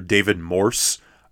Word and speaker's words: Morse: There's the great actor David Morse, and throughout the episode, in the second half, I constantly pronounce his name as David Morris. This is a major error on my part.